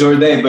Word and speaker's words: Jourdan: Yeah, it's Jourdan, but it more in the French manner, we pronounce the ending differently.